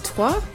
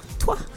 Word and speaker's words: Trois: Yeah, it's Trois. That's how you pronounce it. I know it's a really... Oh, sorry, I probably got it wrong. – No, it is right. – Oh, okay, okay. – Trois.